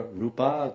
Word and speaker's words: rupa: Rupa is a long A. So gora rupa, gora.